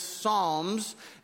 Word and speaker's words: Psalms: If you're not familiar with your Bible, it's not songs, it's Psalms. And it's spelled P-S-A-L-M, Psalms.